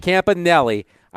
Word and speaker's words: Campanile: Campanile.